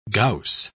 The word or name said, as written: gauss